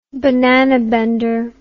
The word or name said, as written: banana bender